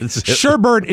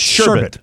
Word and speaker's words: sherbet: Mike, sherbert is incorrect. It's a common misspelling based on a common mispronunciation. – It's sherbet. – Sherbet. – Sherbet, like sorbet.